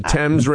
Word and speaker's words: Thames: the Tamas River. – The Thames River in England.